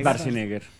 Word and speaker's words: Schwarzenegger: Schwarzenegger. – Es Schwarzenegger.